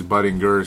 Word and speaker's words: Budinger: There's a million ways to pronounce the name, but I guess it is Budinger since he said so.